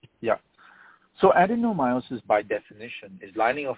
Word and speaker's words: adenomyosis: And can you clarify, because I never know how to say it, adenomyosis. – Yeah, you got it right. – Adenomyosis, that's how it is, how you say it.